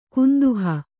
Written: ಕುಂದುಹ

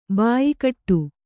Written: ಬಾಯ್ಗೂಡು

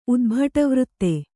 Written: ಉದ್ಭಟವೃತ್ತೆ

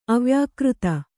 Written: ಅವ್ಯಾಕೃತ

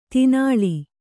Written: ತೀನಾಳಿ

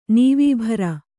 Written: ನೀವೀಭರ

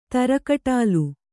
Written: ತರಕಟಾಲು

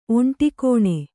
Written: ಒಂಟಿಕೋಣೆ

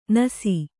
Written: ನುಸಿ

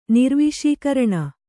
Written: ನಿರ್ವಿಷೀಕರಣ

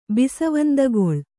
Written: ಬಿಸವಂದಗೊಳ್

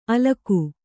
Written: ಅಲಕು

